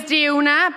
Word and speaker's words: Diona: Yeah, my name's Diona. People often pronounce it wrong, though I do get like Diana, Donna, homewrecker.